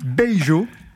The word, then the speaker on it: Beijo: On va écouter tout de suite Beijo.